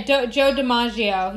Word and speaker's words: Joe DiMaggio: married to her second husband at the time, Joe DiMaggio. I think that's how you pronounce it. – Yeah, Joe DiMaggio.